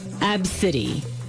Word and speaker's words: ABCDE: And she's named her daughter, you spell it A-B-C-D-E. That's her daughter's. That's how you spell the name. It's pronounced Abcity. – Abcity. – Abcity. – A-B-C-D-E.